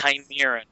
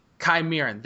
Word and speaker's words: Chimeran: Set in a brutal alternate 1950s universe, America has been successfully conquered and destroyed by the deadly Chimerian. Uh, I cannot pronounce that name for some reason. Chimeran race. Chimeran. Chimeran. Thank you.